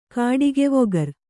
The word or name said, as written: ಕಾಡಿಗೆವೊಗರ್